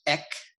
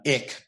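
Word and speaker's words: ek (ἐκ): We would we would pronounce it ek, not ik, but ik, but ek, ek,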